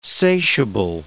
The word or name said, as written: satiable